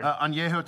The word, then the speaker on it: añejo: Añejo tequila.